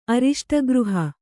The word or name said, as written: ಅರಿಷ್ಟಗೃಹ